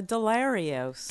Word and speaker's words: Delarios: Lisa Delarios. – D-E-L-A-R-I-O-S.